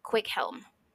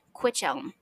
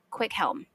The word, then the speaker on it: Cwichelm: There is an Old English name that was very, very common, and it is pronounced Quickhelm. But it is, I'll tell you how it's spelled. C-W-I-C-H-E-L-M. And when the modern English reader looks at this, they want to say Quichelm. However, again, because C-H is not a digraph, we have to pronounce the C and H separately, meaning that this name is actually pronounced Quickhelm.